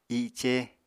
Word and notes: i7ce: apatani

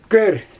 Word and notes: kör: Xyzyl

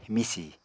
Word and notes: misì: Apatani

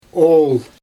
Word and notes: ool: Xyzyl

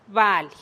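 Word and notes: bali: Teotitlán del Valle Zapotec

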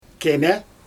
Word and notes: kemE: Xyzyl

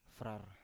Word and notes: frar: Biak

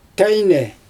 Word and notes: tajnɛ: Xyzyl

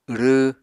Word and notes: wrww: Apatani

